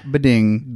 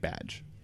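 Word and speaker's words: B'dg: It's not Bading. – Bading, yeah. How do we find that out? Did someone reply us on Twitter about that? – No, it was in Green Lantern 2 issues ago. They actually like had a phonetic version? – Yeah, they said that it's pronounced like badge.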